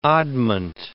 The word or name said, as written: oddment